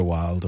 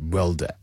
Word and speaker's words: Wilde: I was like, it's pronounced Wilder. I wasn't correct, but yeah, it was a lesson learned.